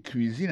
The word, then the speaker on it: cuisine: Autant de cuisine là-dedans, c'est comme ça on dit cuisine, on prononce cuisine en anglais. C-U-I-S-I-N-E. C'est cuisine.